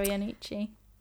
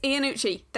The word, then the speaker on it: Iannucci: Armando Iannucci. Well, I don't know how to say his name. – Armando Iannucci. – Yes, Iannucci. That's how you say it.